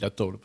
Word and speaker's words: La Taula: Ça s'écrit La Taula. Mais c'est La Taula. – La Taula. – On est des Occitans.